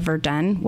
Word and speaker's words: Verdun: I was wondering if maybe you would tell everyone just really briefly what the Battle of Verdun was.